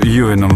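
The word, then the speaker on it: Ewan: как Эван Макгрегор был всю жизнь Эваном, а потом Юином вдруг стал.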